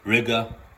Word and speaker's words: Riga: So today's video is titled Chicken in Lviv, Ukraine is cheaper than in Riga, which rhymes with nigga.